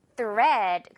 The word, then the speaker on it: thread: 오늘은 EA가 A로 발음 나는 날. 그래서 어떻게요? thread.